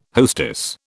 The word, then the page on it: hostess: British English